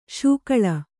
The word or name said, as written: ಶಕಳ